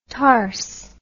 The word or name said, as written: tars